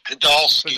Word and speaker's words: Podolsky: And Ken, let me just correct you on the pronunciation of my name. It's Rich Podolsky.